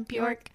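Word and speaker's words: Björk: Is that how you say it? Yeah, Bjork.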